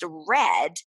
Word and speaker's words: read: R-E-A-D. Mm-hmm. The past tense is also spelled R-E-A-D, but it's pronounced red.